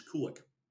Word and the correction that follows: Karlik: Sorry, I mispronounced it on our way out of the last break. It's Karlik, K-A-R-L-I-K, kind of like garlic, I guess.